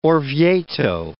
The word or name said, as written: Orvieto